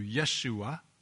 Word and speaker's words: Yeshua: Joshua, probably more accurately pronounced in the Hebrew Yeshua, is the Hebrew name which the New Testament translates Jesus in Greek or Jesus in English.